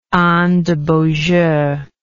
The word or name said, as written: anne de beaujeu